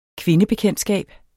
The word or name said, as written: kvindebekendtskab